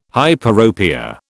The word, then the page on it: hyperopia: British English